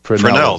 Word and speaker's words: Fresnel: Fresnel, that's right. – It's a Fresnel lens. F-R-E-S-N-E-L. You don't pronounce the S.